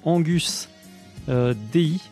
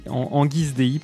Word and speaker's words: Anguis Dei: Hein, donc le groupe s'appelle Angus Dei, Angus Anguis Dei, pardon, je sais pas exactement comment ça se prononce, c'est du latin. Le serpent de Dieu, Anguis Dei, ça s'écrit A-N-G-U-I-S, plus loin D-E-I.